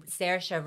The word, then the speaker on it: Saoirse: Saoirse rhymes with inertia.